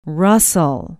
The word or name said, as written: Russell